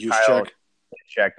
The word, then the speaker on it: Juszczyk: Juszczyk, thank you.